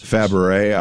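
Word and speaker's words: Fabares: And the other day they pronounced it different Fabrizé. – I had never said it that way for about 50 years, but that's okay. Yeah, that's how you pronounce it.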